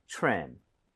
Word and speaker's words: trim: TRE 붙어 있어요. TRE 붙어 있고 그다음에 모음으로 연결이 되면 크리스마스 트리처럼 치읓 비슷해집니다. – 그쵸. – 그래서 입 모양을 모으고 발음해 주세요. 가보겠습니다. Trim, trim.